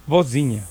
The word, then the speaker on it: Vozinha: Sí, Bocinha, con V. Bocinha, pues sí sabes cómo se escribe. – No. – V-O-Z-I-N-H-A. Bocinha. – Z-I-Latina. – Sí. Z-I-N-H-A. Bocinha.